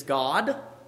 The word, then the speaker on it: Gad: And so she names him, we say Gad, the proper pronunciation is God, actually.